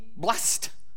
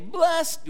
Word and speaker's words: blessed: So the example would be, before we ate, our Uncle Tony blessed the meal, right? You got it. Rule two is when the word blessed is used as an adverb or a noun, it's pronounced with two syllables.